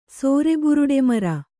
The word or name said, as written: ಸೋರೆಬುರುಡೆಮರ